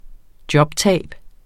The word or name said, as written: jobtab